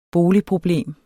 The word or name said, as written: boligproblem